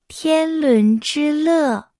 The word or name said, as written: 天伦之乐